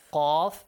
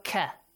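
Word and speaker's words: Qaf (قاف): Qaf has a heavy sound. Qaf. Yes. Qaf. Make sure it's not Ka. We don't have this equivalent of Qaf in English.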